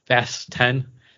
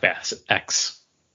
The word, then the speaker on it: Fast X: part of Fast 10 to let people know that, hey, it's pronounced Fast 10. It's not Fast X.